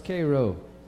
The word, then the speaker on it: Cairo: It's not pronounced Cairo, it's Cairo.